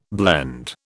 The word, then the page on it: blende: British English